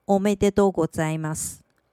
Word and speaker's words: おめでとうございます: 日文的恭喜念成おめでとうございます。